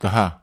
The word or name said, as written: taha